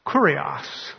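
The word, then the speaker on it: kurios (κύριος): That word he used, Lord, is pronounced kurios.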